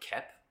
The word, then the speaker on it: Koepp: Okay, so David, and I'm so sorry, I can't pronounce his last name, but is it like a Cope? – Kep. – K-O-E-P-P.